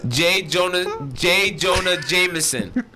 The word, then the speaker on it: J. Jonah Jameson: J. Jonah Jameson.